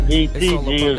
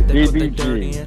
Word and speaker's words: DBG: It's not DPG, it's DB. Like, boy, DBG.